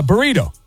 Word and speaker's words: burrito: It's a burrito, you know. – Burrito. – Yeah. All right. – Okay. – Don't forget to roll your R's.